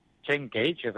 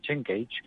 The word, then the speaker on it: Chen Kaige: Chen Kaige, Chen Cage, perquè això als xinesos sempre costa una miqueta de pronunciar, Chen Cage,